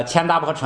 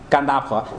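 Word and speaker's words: 乾闼婆: 如热实焰，如这个千达婆城，咱们都读千达婆，按这个梵文发音其实更近于干，哎，干达婆，咱们还按传统啊，这个读什么无所谓，就是说如热实焰是什么呢？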